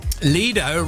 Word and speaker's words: Lido: It's pronounced both ways, as I said. – Lido rhymes with Speedo. – Or Dido. – Not Dido. Not Dido. – Stay out of this. – But there's Lido rhymes with Dido.